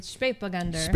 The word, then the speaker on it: Spätburgunder: Say it again? – Spätburgunder. – Spätburgunder. – I'm probably saying it very Texan-like. Well, Spätburgunder.